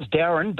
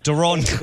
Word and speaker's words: Daron: So, yeah, it's Darren, but it's spelled D-A-R-O-N. – Okay. – So I get called Daron. – Daron.